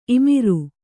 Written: ಇಮುರು

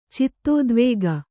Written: ಚಿತ್ತೋದ್ವೇಗ

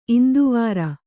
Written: ಇಂದುವಾರ

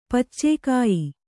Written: ಪಚ್ಚೆಕಾಯಿ

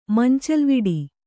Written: ಮಂಚಲ್ವಿಡಿ

